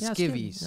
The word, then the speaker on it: skivvies: By the way, skibbies. Is that how you say it? Or is it skivvies? – Skivvies. – Skivvies, yeah. – Skivvies. Okay, okay. Yeah, skivvies.